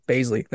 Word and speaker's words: Bazley: And then the Suns got Darius, I think it's uh, Bosley. Bosley? – Probably said that wrong. – Bazley. There you go.